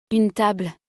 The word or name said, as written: une table